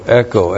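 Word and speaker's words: echo (ἔχω): Which virtue love, and then the verb echo, E-C-H long O, echo.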